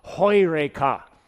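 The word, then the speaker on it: heureka (εὕρηκα): The word in Greek that Archimedes shouted when he discovered this law of physics was, in Greek, pronounced heureka. In English, we'd say eureka.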